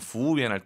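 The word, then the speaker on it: 服务员: 재밌는 건 이 저만의 팁을 드리면 부우연 할 때 그 우를 발음하지 말아보세요.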